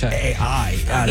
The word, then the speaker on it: Pichai: The most he did as far as talking AI was make a joke that the last two letters of his name, Pichai, are AI. Sound like AI. – It is Pichai.